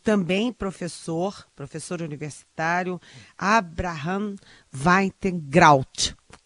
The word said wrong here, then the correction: Weintraub: Como é que é? Hum? Weintraub. – É, eu acho que eu falei certo. Weintraub.